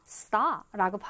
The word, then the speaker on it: saw: 요거를 우리 친구들이 see 과거 saw인데 so 이렇게 말하면 안 돼. 그럼 안 들려요. 그래서 saw라고 발음이 되는구나.